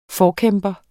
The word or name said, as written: forkæmper